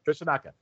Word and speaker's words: Koshinaka: Did I pronounce that right? O'Hara and Koshinaka? – Yeah, that's right. – Koshinaka.